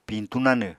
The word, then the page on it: piñtú nanw: Apatani